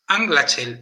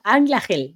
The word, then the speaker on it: Anglachel: Escrito Anglachel. Espero que recordéis las lecciones de los dos últimos meses. La CH se pronuncia J. – Anglachel, venga, muy bien.